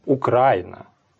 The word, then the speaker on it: Украина: Правильно, да. Оно так и должно. Украина.